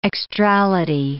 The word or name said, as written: extrality